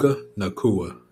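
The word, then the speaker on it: Puka Nacua: How do you say that boy's name? – Nakua? Puka Nakua.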